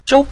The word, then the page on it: zhou: zhou5